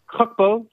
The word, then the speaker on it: Gakpo: Gakpo.